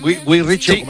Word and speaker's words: Guy Ritchie: Lo he dicho bien, Guy Ritchie.